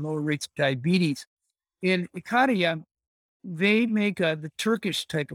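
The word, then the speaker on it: Ikaria: And is it Icaria? I can never pronounce it. Is it Icaria or Icaria? How do I pronounce it? – Icaria. – Icaria.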